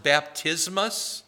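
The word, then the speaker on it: baptismos (βαπτισμός): It's pronounced baptismos in Greek.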